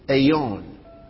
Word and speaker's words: aion: So the Greek word for that word or age will be aeon. How do you spell that? A-I-O-N. Aeon.